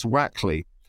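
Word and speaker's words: Wakley: Well, the first thing to say, Martin, is there's a lot of controversy about how to say his name. So we have been brought up at The Lancet to call him Thomas Wakeley.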